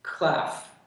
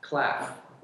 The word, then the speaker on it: Klaff: Klaff. Klaff.